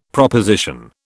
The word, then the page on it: proposition: British English